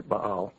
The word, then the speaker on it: Baal: I'm not stuttering. That's actually the way it's supposed to be pronounced. It's a double A there, Baal.